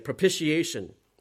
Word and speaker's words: propitiation: Now, one of the kids read this word, and they did a great job pronouncing it, propitiation.